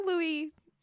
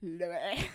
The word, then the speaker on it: Louis: Well, because they don't pronounce the last letter, right? It's like Louis. – Louis. – Louis.